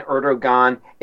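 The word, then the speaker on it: Erdoğan: So Turkey, the way I see it, the name Erdogan, it is spelled with a G, although I think in Turkish it's a G with a crescent-shaped accent mark over it. According to Wikipedia, that is pronounced W.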